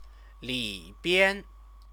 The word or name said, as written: libian